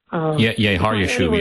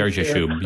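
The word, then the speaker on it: Shear-jashub: Is it Jer Jashub? Am I pronouncing that? Or Jer Jashub? – Har Yashub, yeah. – Very close. Okay, well, you're the Yeah, Har Yashub, yeah.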